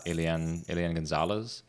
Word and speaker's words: Elian Gonzalez: So I think it was in 1999 when Elian Gonzalez...